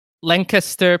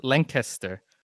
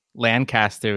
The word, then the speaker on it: Lancaster: And that's how you say it, Lancaster. It's spelled, it looks like Lancaster, right? Exactly how you would think Lancaster would be pronounced, but it's Lancaster, Pennsylvania. And if you say it wrong, the amount of mocking and ridicule that's directed your way is it's really undeserved.